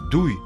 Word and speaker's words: 对: Слово «дуй» произносится в четвертом тоне, переводится как «направлять», «нацеливать против», «воздействовать на», «адресовать».